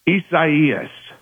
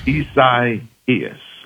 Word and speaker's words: Isaias: Isaias. – Okay, that makes sense. – Isaias.